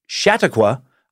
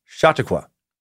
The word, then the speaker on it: Chautauqua: pronouncing it Chautauqua. I must inform you that it is actually pronounced with a softer Chautauqua, or yeah, or Chautauqua.